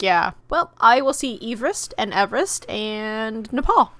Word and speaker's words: Everest: Everest, because it's named after a person whose last name was Everest. Like, together.